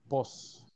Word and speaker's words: pues: ¿Conocen la forma en que se, en que se pronuncia en algunos, en algunos países? – En Chile dicen po. – Correcto. En Chile, acá dicen po. En México, pos.